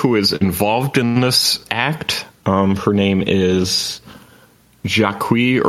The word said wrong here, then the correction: Jacqui: Irwin. I'm probably not pronouncing that right. – It's probably Jackie. – Jackie, yeah. – Oh, Jackie. Okay.